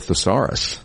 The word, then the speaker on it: thesaurus: I need a thesaurus. – Thesaurus. – A thesaurus. Got the emphasis on the wrong syllable.